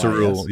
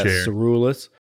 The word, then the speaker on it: curulis: The Egyptian version was the, I'll say this wrong, it's the Cerulis, C-U-R-U-L-I-S. Cerulis, yeah. – Yeah, Cerulis.